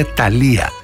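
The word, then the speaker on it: Thalía: Τα χειροκροτήματα δεν ήταν για το γεγονός αυτό, αλλά ήταν για μια σπουδαία μεξικάνα τραγουδίστρια, τη Θάλια, την οποία τη λένε Ταλία, γιατί δεν προφέρουν το TFH που είναι θήτα, το κανονικό της όνομα.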